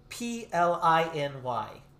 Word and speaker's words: Pliny: This name kept popping up, and it was Pliny the Elder. Pliny. Pliny the Elder. – Spell it for me. – P L I N Y.